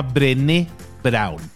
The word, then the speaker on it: Brené Brown: escrito por una señora que se llama Brené Brown, tal cual suena con las cinco letras Brené y con acento en la E final, Brené Brown.